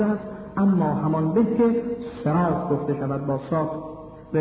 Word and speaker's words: صراط: میتوان اول اون رو هم به سین تلفظ کرد، هم به ز تلفظ کرد، هم به صاد تلفظ کرد. همان گونه که شما عزیزان در کلمه صراط